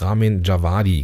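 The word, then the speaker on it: Ramin Djawadi: Ramin Javadi, genau.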